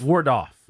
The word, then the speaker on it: Waldorf: How do you say it? Wardorf? – Wardoff.